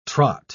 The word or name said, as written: trot